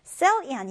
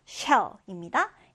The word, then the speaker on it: shell: 이 단어는 여러분 발음 주의해 주세요. cell이 아니라 shell, shell입니다. 이제 우리말로 한번 써본다면 shell 이렇게 써볼 수가 있겠는데, 자, 다시 한번 써볼게요. shell 이렇게 발음해 주면 돼요.